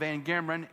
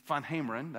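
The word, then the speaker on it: VanGemeren: we called him Van Gameren. He was Dutch, so Van Hameren, that's the way you're supposed to pronounce it.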